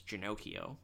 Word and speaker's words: Ginocchio: you know, my last name is Ginocchio, which mm-hmm. rhymes with Pinocchio, the Disney character based on an Italian folktale.